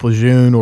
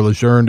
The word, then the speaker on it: Lejeune: You know, industrial compounds, defoliants like Agent Orange, burn pits, contaminated water in places like Camp Lejeune or Lejeune, depending on how you pronounce it.